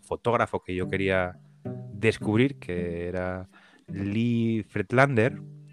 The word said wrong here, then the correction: Friedlander: Friedlander, no sé exactamente cómo se dice,